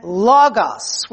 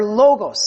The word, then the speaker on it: logos: It comes from two Greek words, doxa, which means glory, praise, or appearance, and logos, which means, or logos, some people pronounce it, which means word, saying, or utterance.